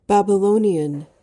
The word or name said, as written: babylonian